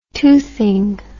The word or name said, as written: toothing